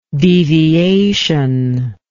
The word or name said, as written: deviative